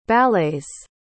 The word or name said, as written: ballet's